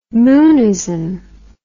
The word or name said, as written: moonism